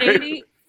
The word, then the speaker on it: Freire: Freire, no, Freire is correct. You said it right the first time.